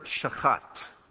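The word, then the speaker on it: shachat (שחת): It's the word shachat. Shachat. So you have to get a lot of ch in there.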